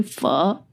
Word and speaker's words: pho: So an example where that is relevant is when you make pho or pho for the North Americans, but it's actually pho. Learn how to say it.